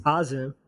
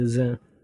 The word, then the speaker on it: Azim: And she named me, technically, it's pronounced Azim. But it just sounds weird to me when, like, non-Desi people say my name.